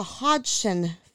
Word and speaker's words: Hodgson: And involves the Hodgson family.